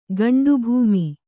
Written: ಗಂಡುಭೂಮಿ

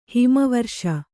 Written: ಹಿಮವರ್ಷ

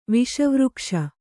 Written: ವಿಷವೃಕ್ಷ

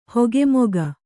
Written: ಹಿಗ್ಗಾಮುಗ್ಗಾ

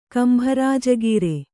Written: ಕಂಬರಾಜಗೀರೆ

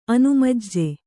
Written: ಅನುಮಜ್ಜೆ

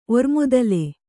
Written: ಒರ್ಮತ್ತಲ್

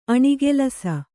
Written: ಅನುಕಲಿಸು

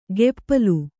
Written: ಗೆಪ್ಪಲು

